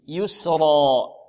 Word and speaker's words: يسرى: يسرى. لماذا فخمنا الراء هنا؟ لأنها مفتوحة والسين قبلها مكسورة مرققة.